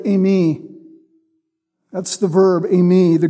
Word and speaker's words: eimi: Instead of the simple word eimi, I am, he would add the pronoun ego, ego eimi, I am. The stress is on the I.